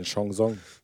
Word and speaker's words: Chanson: Ein Chanson. – So wird es korrekterweise ausgesprochen.